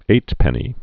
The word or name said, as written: eightpenny nail